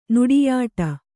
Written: ನುಡಿಯಾಟ

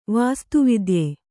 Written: ವಾಸ್ತುವಿದ್ಯೆ

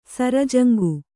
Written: ಸರಜಂಗು

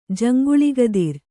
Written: ಜಂಗುಳಿಗದಿರ್

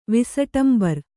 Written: ವಿಸಟಂಬರಿ

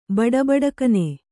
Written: ಬಡಬಡಕನೆ

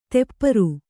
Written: ತೆಪ್ಪರು

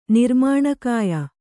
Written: ನಿರ್ಮಾಣಕಾಯ

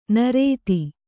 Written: ನಿರುತಿ

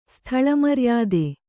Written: ಸ್ಥಳಮರ್ಯಾದೆ

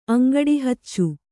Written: ಅಂಗಡಿಹಚ್ಚು

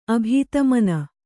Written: ಅಭಿಧಮನಿ

